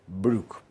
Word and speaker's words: Broek: Broek, que se pronuncia Brook, porque el diptongo OE en neerlandés se pronuncia U, como en Boer, que se pronuncia Bur.